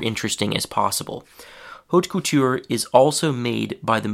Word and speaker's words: haute couture: That's the French pronunciation. In English, we'd probably just say haute couture. Um, and haute couture is the practice of creating extremely high quality, high fashion clothing.